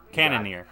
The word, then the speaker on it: Cannonier: Zombie versus Jared Cannonier. Cannonier. You got it.